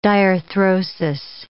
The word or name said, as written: diarthrosis